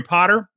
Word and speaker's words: Potter: My name is Jerry Potter. Yes, it rhymes with the boy wizard.